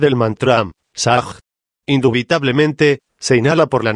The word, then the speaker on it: SAH: AM se escribe con las letras H, A, M. SA se escribe con las letras S, A, H. La H suena siempre como J.